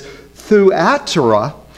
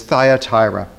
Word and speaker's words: Thyatira: I even looked up just to make certain that I was pronouncing that correctly, and found that some people pronounce it Thyatira. The Greek pronunciation is Thuatira, but most of the English pronunciation is Thyatira.